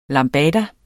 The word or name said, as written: lambada